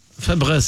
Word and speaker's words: Febreze: Ben non, il y a un E au début et un E dans Fabrice, ça s'écrit F-E-B-R-E-Z-E. Il n'y a pas de... – Fabreuse. – Fabreuse, c'est ça. Mais tout le monde va l'écrire en mettant trois. – C'est vrai que ça sent bon, du Fabreuse.